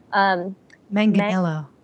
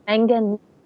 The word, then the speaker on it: Manganiello: I didn't even try because his name is so long, but it's Manganiello. – Manganiello.